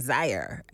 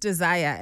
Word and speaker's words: Dzire: But the way it's spelled D-Z-I-R-E. So in your head, you're going Zire. – And then, Zire. – Yeah. And then you're just like, no, it's actually, Zire. It's pronounced Desire.